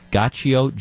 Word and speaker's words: Giaccio: I think you pronounce his name Gaccio, G-I-A-C-C-I-O, Frank Gaccio, 10-year-old from Falls Church, Virginia, had sent to President Trump.